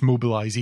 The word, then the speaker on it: Mobilize: and it's it's pronounced Mobilize even in French.